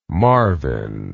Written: marvin

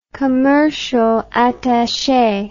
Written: commercial attache

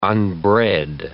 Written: unbred

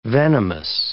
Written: venomous

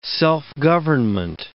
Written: self-government

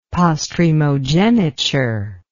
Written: postremogeniture

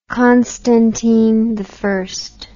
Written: Constantine I

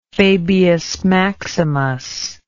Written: fabius maximus

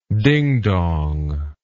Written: dingdong

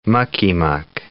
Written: mucky-muck

